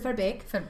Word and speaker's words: Verbeek: It sounded like she was pronouncing her name with a Scottish accent. I'm Lotte Verbeek. – She said Verbeek. – Sorry. – Which to me is Dutch, because the German double E is the A sound.